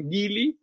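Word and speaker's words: Geely: Eh, pido perdón a, los que ofenda esta declaración, por lo que ya conocía al, gigante Gili, como dicen los sajones, que se escribe Geli,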